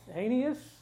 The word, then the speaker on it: Aeneas: His name is Aeneas. A-E-N-E-S. So you say the first two letters as with the long sound, and the next vowel has a long sound, and the last vowel has a short sound.